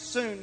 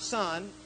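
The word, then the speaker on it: soon (σύν): Okay, soon, which is pronounced soon, not sun. When we write it in English, it looks like sun, but it's pronounced soon.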